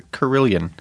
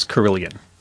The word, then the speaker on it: Carillon: That is how it's pronounced. It's a Carillon. – Okay. – It does look like Carolyn, but it is Carillon.